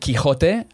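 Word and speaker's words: Quijote: Yeah, so in Castilian Spanish, which is like Madrid, you know, the standard, whatever, you would pronounce it Quixote, and it's got a J in it. So it's Q-U-I-J-O-T-E. – Yes. – Don Quixote, like jamón, right? – Mm. – J-A-M-O-N for ham.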